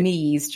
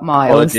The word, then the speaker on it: Mies: It's Andreas Mies, Joe, not Miles.